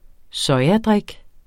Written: sojadrik

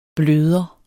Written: bløder

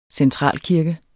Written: centralkirke

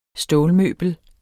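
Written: STÅLMØBEL

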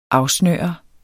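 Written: afsnøre

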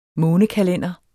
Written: månekalender

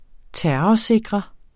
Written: terrorsikre

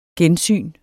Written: gensyn

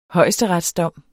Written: højesteretsdom

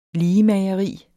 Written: ligemageri

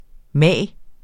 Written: mag

